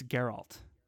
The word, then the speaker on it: Geralt: It's Geralt.